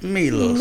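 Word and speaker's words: Miloš: You have to say it like Milos.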